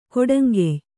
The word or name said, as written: ಕೊಡಂಗೆಯ್